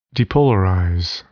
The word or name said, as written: depolarize